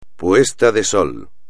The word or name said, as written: puesta de sol